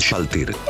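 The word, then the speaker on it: Shalltear: Solo para que quede claro, la pronunciación correcta es Shaltir.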